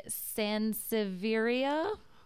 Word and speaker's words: Sansevieria: And it also looks like a Sansevieria. I might be butchering that. – No, that sounds right.